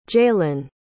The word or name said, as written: jaylen